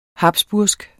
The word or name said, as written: habsburgsk